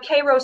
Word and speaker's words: Cairo: For our listeners out there, even though we say Cairo, it's spelled Cairo.